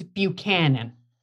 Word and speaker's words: Buchanan: It's Buchanan.